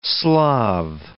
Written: Slav

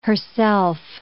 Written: herself

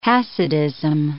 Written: Hasidism

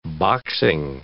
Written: boxing

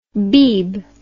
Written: beeb

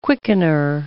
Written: quickener

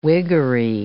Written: Whiggery

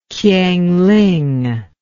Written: Kiangling

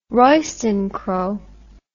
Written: Royston crow

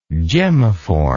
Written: gemmiform

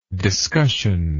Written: discussional

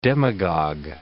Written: demagogue

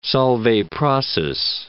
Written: Solvay process

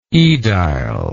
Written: edile

